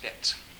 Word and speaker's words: Beetz: That's spelled B-E-E-T-Z, but it's pronounced Betts.